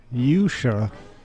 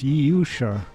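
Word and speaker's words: Jordsjø: You're listening to The New Abnormal here on WPRB Princeton, where we just heard from the Norwegian symphonic Prague band Yusha. It's spelled like Yordsio, but I think it's pronounced Yusha.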